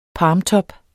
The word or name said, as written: palmtop